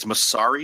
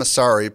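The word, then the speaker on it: Mosseri: A little backstory on January the 11th, which was last Thursday, Mark Zuckerberg and Adam, how do we say his last name? – I think it's Massari. – Adam Massari posted something,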